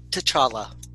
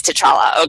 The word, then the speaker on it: T'Challa: Shuri is my new obsession and my daughter's new obsession, the teenage brilliant sister of T'Challa. Is that how you say it? I'm not sure if that's T'Challa. – T'Challa. – It is T'Challa.